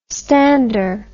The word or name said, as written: stander